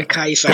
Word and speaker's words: Caesar: Eller Kaiser som det korrekta uttalet är.